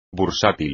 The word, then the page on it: bursátil: espagnol